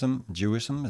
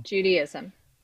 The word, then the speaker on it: Judaism: Why would, for for example, if someone would convert to, to Jews, Jewism, is that how you say it, or Juden? – Judaism. – Judaism, sorry, yeah, mm-hmm.